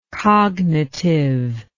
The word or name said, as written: cognitivity